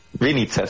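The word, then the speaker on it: Vinnytsia: No sé, ¿en Vinitsa? – Vinitsa, sí, Vinitsa.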